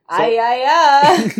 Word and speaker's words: Aiaia: A-I-A-I-A. So there are two Yas. – Aya-ya.